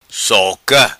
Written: Soca

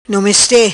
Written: Nomeste

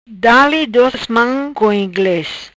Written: Dale dos mam co ingles

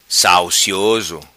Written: Salcioso

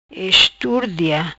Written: Esturdia

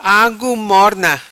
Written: Agu Morna

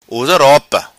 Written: usa ropa

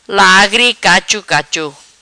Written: Lagri Cacho Cacho

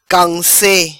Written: Camse

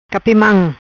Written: Capi Mam

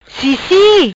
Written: Cissi

